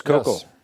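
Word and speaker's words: Koukl: I'm taking a class through my church, and one of the books that we're reading is called Tactics by Greg Kokel. I don't know how you pronounce the name. – Yes, Kokel.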